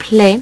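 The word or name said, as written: plaît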